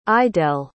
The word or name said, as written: idelle